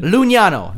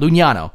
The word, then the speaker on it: Lugnano: Lugnano. There we go. I actually listened to how it would be pronounced. So, because that's where it is, Lugnano.